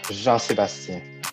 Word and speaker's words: Jean-Sebastien: I'm Jean-Sebastien. Thank you so much. – Let's hear JS, say your name one more time. Just Jean-Sebastien.